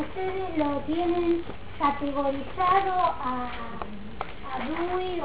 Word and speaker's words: Dewey: Los que más saben inglés dicen que se pronuncia Dewey.